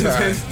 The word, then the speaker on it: hentai: Hentai. – It's hentai? – Like you're tying a hen.